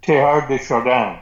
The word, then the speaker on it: Teilhard de Chardin: Teilhard de Chardin.